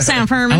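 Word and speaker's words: San Fermín: I don't think I like San Fermin. – I like Fermin better. I'm going to say that with confidence, okay? F-E-R-M-I-N.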